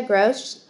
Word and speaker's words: Grose: So my website is Jessica Grose, G-R-O-S-E.